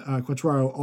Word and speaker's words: Quatraro: and also Matt Quatraro, I believe that's how you pronounce it, Quatraro, are the three candidates that are in the second round of interviews.